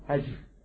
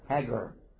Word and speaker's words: Hagar: It's mentioned in the Bible, Ishmael, and the mother of Ishmael, Hagar. Only we called her Hagar. And that's okay, because in Cairo, Egypt, they also say Hagar. But we say Hagar. Different way to pronounce the G.